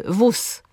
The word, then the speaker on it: wóz: Mówimy na przykład nóż, mimo że piszemy ż, wóz, mimo że piszemy z, nie widzę gwiazd, mimo że tam jest z i d, obserwuję bieg, a nie g, piekę schab.